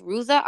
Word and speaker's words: Ruza: We don't really have sponsorships yet, but this week is gonna be I I want to say it's pronounced Ruza R U Z A Ruza.